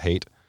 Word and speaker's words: Haidt: One was, there was a guest on the Joe Rogan podcast named Jonathan Haidt, and it's H-A-I-D-T, weird spelling. – Like Haiti? – Like, yeah, Haidt, but it's pronounced hate.